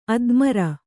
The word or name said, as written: ಅದ್ಮರ